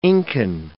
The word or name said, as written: Incan